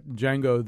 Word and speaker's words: Django: I know one of you listed Django Unchained as a great tra- trailer, but when when they do the thing about Django, the D is silent, yeah.